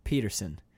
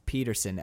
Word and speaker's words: Peterson: Peterson. It's not James Bond. You know, it's like monosyllables, powerful. I'm like, it's Max Peterson, S-O-N, not S-E-N, asshole.